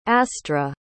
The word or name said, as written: astra